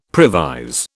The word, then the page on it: previse: British English